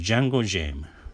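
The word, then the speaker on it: jungle gym: これはね、えー、まあ発音は似てるんですが、英語では、えー、ジャンゴジム、ジャンゴジムですね。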